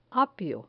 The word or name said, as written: apio